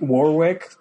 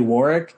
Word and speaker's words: Warwick: So Warwick is like Warwick that Americans say, but it's actually Warwick in like in the UK.